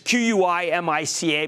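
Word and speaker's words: Química: I don't call me skeptical, but Sociedad Quimica, which is Q U I M I C A, but it's pronounced Quimica, the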